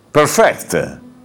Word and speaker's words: perfect: Perfect. Perfect quando l'accento è sull'ultima, cosa significa? Perfezionare, ok.